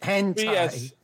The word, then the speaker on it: hentai: How do you pronounce it? – Hentai. – Yes, that you got kicked off of TikTok.